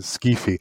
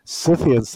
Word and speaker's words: Scythians: you might get the habit of pronouncing Skifi. Of course, to the Greeks they were Skufoi. – The Greeks would have pronounced the Kappa, and I think Herodotus says that the Scythians themselves called them the Skolotoi. – I hope you excuse me if I will pronounce this Scythians.